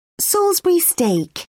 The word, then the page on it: salisbury steak: British English